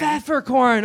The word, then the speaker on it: Pfefferkorn: Pfefferkorn. Oh, I see. P-F-F-E-R. – Silent P.